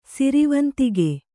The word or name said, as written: ಸಿರಿವಂತಿಕೆ